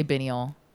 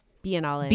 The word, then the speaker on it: Biennale: How do you say Bienal? Bienal? – Biennale. – Biennale. There we go.